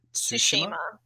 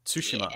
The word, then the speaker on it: Tsushima: Ghosts of, how did they pronounce it? Was it Tsushima? Tsushima. Yeah. I've been calling it Ghost of Tsushima, but they pronounce it Tsushima. – I think that's just the accent, so the American accent versus the Australian accent.